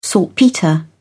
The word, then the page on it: saltpetre: British English